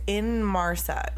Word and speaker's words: Inmarsat: Talk about the Inmarsat data. I hope we're pronouncing that correctly. – It's Inmarsat, like I N M A R S E T.